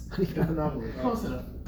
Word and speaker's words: Stephanopoulos: I can't pronounce his name, George Stephanopoulos? – Stephanopoulos.